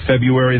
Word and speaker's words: February: So all the years I've been saying February, that's not correct. – February. – You actually have to put the R in there.